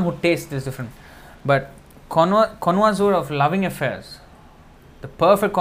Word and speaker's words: connoisseur: The exact word is connoisseur. Con- connoisseur. Is that the, is that how it's pronounced? C O N N O I S S E U R. Connoisseur. It's actually a French word.